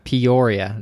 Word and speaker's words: Peoria: Peoria. That's how you say it.